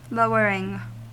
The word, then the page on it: lowering: American English